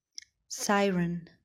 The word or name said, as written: siren